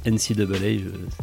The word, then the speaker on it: NCAA: Voilà, ouais, alors juste avant, on prononce quand même NCAA.